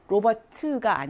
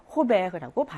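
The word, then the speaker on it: Robert: 끝장은 발음이 나지 않은 경우가 많아서 이것도 로버트가 아니고 호베흐라고 발음이 되는 거죠.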